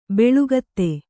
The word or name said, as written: ಬೆಳುಗತ್ತೆ